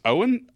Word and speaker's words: Oen: So it's one of the Bruny Island cheeses called, I think it's pronounced Owen, O-E-N.